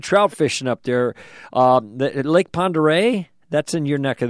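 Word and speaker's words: Pend Oreille: But in any event, it looks like Pend Aurelles is the way they spell it, but I think they call it Ponderay.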